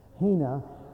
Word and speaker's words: hina: But that is the way to pronounce it, isn't it? Hina.